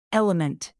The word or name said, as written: element